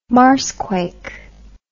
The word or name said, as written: marsquake